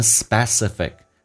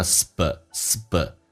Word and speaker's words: specific: 那么这边有个单词，估计有人听得不是很明白，因为我们通常会把这个单词读错了，读成 specific，实际上这个单词叫 specific Thanksgiving，有个特别的感恩节，特殊的感恩节，就变成 I can 轻轻点一下 I can remember a specific Thanksgiving where there's no money for food。